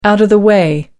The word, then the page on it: out of the way: British English